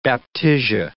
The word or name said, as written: baptisia